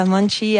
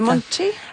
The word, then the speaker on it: Monte: Oui, Marisa Monte avec son Ok, m'importa. – On dit Monte? – Monte. – Monte, ça s'écrit M-O-N-T-E pour les intéressés.